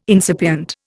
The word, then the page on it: incipient: Indian